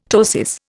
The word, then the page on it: ptosis: Indian